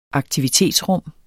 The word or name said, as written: aktivitetsrum